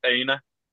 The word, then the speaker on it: Aina: I think it was Aina, is how you pronounce it.